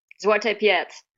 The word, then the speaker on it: Zwarte Piet: is Zwarte Piet. Am I pronouncing that correctly? – Black Peter. Zwarte Piet. Yeah, it's very controversial.